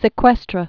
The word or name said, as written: sequestra